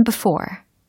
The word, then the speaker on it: before: I've never been before. I've never been before. 네. I've never 이렇게 I've never이 아니라 I've never never 이 부분이 포인트가 되고요. 그다음 before에서 그 for 부분을 강하게 어, 발음을 해주셔야 됩니다.